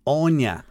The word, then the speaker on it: Áine: It's the Irish version of Anne, and it's pronounced Onya.